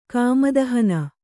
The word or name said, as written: ಕಾಮದಹನ